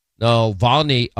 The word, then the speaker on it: Navalny: You've heard about this Russian opposition leader, Alexei Navalny.